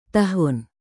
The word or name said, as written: tahun